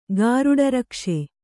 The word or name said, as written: ಗಾರುಡರಕ್ಷೆ